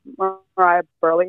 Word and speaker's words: Mariah Burley: My YouTube channel is just my name, Mariah Burley.